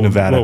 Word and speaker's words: Nevada: Whoa, Nevada. Whoa. Please say it right. – Nevada. – Yeah, there you go.